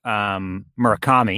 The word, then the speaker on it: Murakami: Not only was it a box logo tee, but it was a collaboration with Murakami.